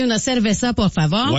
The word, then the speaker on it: cerveza: Puis là, il y a quelqu'un qui a dit au 6-12-12, faut que les Québécois arrêtent de prononcer cerveza avec un V, même si ça se lit comme ça, ça s'écrit comme ça. Mais le V en espagnol se prononce comme un B.